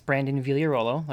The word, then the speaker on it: Vigliarolo: Yeah, so my name is Brandon Vigliarolo, like you said, and you pronounced it perfectly.